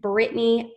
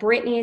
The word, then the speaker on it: Brittney: You're welcome to connect with me on LinkedIn, Brittney Lincoln, but Brittney is spelled Brittney.